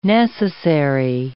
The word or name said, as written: necessary